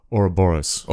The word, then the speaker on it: Ouroboros: or was it an Ouroborealis? Oh, Robro, oh, oh, or, Ouroboros.